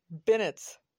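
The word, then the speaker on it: beignets: Like, I had no idea until today that those things are pronounced Bennett's. I've been saying it wrong the whole time. Well, it is beignet in French. Right, and as you know, New Orleanians do not strictly speak French. It's like y'all pronounce French words like you would in English.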